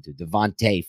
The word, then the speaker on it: Devonta: Just go find that interview where he confirms that it's Devonta.